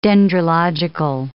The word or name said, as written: dendrological